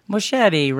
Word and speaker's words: Moschetti: Moschetti rhymes with spaghetti.